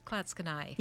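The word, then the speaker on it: Clatskanie: But Klatskanai is spelled C-L-A-T, Klatska, S-K-A, Nye, N-I-E, that one's, or knee. How do you pronounce it? – Klatskanai. – Yeah, but that one could go either way.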